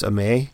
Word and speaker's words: Amer: The owner of Mavic is Amer? What? How do they say that? – I think most properly it's Amay.